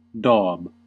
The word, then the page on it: daub: American English